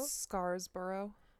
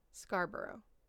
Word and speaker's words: Scarborough: And so the Scarborough rapist is born. – Mm-hmm. – I'm sure I'm not saying that right. Scarborough? – Scarsborough. – There's no double, there's not another S. Oh. Scarborough.